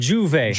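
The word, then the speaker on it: J'Ouvert: Is I say that right? – Jouvet. – Jouvet? – Yeah, Jouvet.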